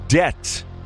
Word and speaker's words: debt: The keyword is debt. D-E-B-T. Debt. The B is silent, but you won't be if you win.